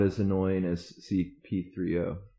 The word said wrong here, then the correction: C-3PO: Or C-3PO, even. – C-3PO. Did I say it wrong? – Yeah. You did.